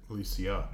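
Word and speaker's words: Alesia: I'm pronouncing that right. Yeah, it's Elysium. – Elysia. – Elysia, because Elysium is heaven. – Yes. – Yeah. – Yeah. And it's an A and not an E.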